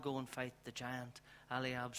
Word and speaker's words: Eliab: You know, we read also, how do you pronounce David's oldest brother? Eliab. Eliab, David's oldest brother.